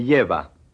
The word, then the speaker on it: lleva: Remember that the double L is pronounced like the English Y. Repeat. – Me lleva.